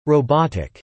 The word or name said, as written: robotic